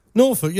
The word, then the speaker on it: Norfolk: Norfolk, yeah, the L right. is silent, yeah, as in cucumber.